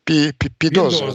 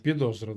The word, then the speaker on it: Підозра: Предъявить ему, как это есть какое-то замечательное украинское слово, трудно его произносить, Підозра, да? – Підозра, да.